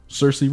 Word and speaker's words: Saoirse: It's spelled S-A-O-I-R-S-E, something like that. But I bet you it's like Cersei or something. It's Irish. What are you going to do? – Yeah. – Saoirse Ronan, Saoirse Ronan.